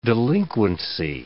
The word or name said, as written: delinquency